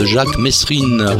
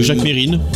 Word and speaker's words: Jacques Mesrine: 2 novembre 1979, c'est la mort de Jacques Mesrine, qu'on appelle aussi... – Jacques Mérine. – Jacques Mérine. – Enfin, les deux prononciations se disaient à la télévision.